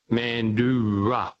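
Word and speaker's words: Mandurah: It's an indigenous name, and it's pronounced Mandura.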